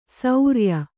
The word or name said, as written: ಸೌರ್ಯ